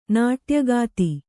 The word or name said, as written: ನಾಟ್ಯಗಾತಿ